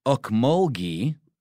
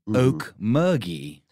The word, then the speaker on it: Ocmulgee: saying here in Georgia, they call the old muddy river Okmulgee, Okmulgee. Okmulgee. – And that's spelled O-C-M-U-L-G-E-E. – But the funny thing is, I am from Georgia, and I've always said Okmulgee, and I've heard it pronounced that way, but whatever you say. And then ghee, like clarified butter, Okmulgee.